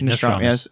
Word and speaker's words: Nostradamus: You said it right. Nostradamus.